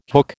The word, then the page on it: hook: American English